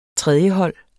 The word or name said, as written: tredjehold